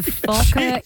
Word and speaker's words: Focker: The Focker in laws. – Just careful. – No, I said. – Yeah, I know, but. – Yeah, that's it. You said it right.